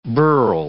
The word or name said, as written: bharal